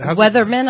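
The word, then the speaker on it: Werthmann: Is it, Weatherman, I believe. – Is it Weatherman? – I believe is how it's pronounced.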